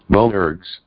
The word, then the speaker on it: Boanerges: Boanerges.